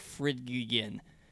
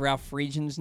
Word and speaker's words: Friedgen: But I think the decision came after you called Ralph Friedge and Ralph Friedgegen. That was working in Baltimore sports, if you don't know how to pronounce Ralph Friedgen's name, maybe the most important coach in Maryland football history.